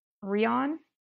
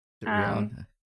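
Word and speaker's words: Rian: I think technically her name is pronounced Rion. – Rion.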